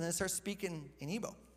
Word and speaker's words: Igbo: Igbo? Is that what you said? Is that how you say it? – Igbo. – Yeah, Igbo. Okay, Igbo.